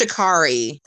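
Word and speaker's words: Sha'Carri: How do you say it? Shakari? – I think it's Shakari.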